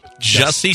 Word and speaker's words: Jussie: The first two guys in line to buy the white power t-shirts are the two MAGA guys that held up Jesse Smollett. – Jussie Smollett? – We're not doing this again. – No. What does Jussie rhyme with? – Sussy.